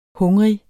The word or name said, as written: hungrig